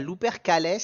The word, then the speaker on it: Lupercales: Je vais dire Lupercales, e L-U-P-E-R-C-A-L-E-S. – Alors je te dirais l'Upercalès, si c'est euh, un peu du latin où il fallait un peu tout prononcer. Euh, ça fait plus stylé. – L'Upercalès, alors on, valide ça.